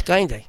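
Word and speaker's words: Gaïndé: how do you say lion in Wolof? – Gainde. – Oh, it's Gainde. Okay.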